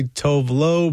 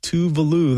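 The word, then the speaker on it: Tove Lo: I was always confused at how to say that artist's name until someone said, hey, just remember that it rhymes with Groovy Sue. So most people would say Tovlo, but it is Tuvalu. That's how it's pronounced. Here's a song that Tuvalu did with Stromae, Diflor.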